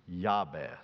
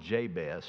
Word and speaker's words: Jabez: His name is pronounced Jabez. Jabez is the correct Hebrew pronunciation for him. But good southern colloquialism, and everybody I know calls him Jabez.